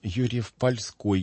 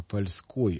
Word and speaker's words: Юрьев-Польской: Возьмите Дмитриевский собор во Владимире, а возьмите Юрьев-Польской, я подчеркиваю ударение, только такое, может быть, не польский, а польской, от слова «поле».